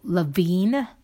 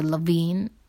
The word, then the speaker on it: Lavigne: Right, so I was since I was talking about Avril Lavigne. I don't know why she has a G in her last name. If you say Lavigne, why is there a G? L-A-V-I-G-N-E. So the G is silent, I believe.